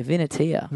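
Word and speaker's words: Vinatea: V-I-N. – A-T-E-A. – A-T-E-A, Vinatia.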